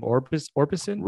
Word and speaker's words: Orbison: Orbison.